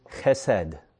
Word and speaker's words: chesed: It's a word pronounced chesed.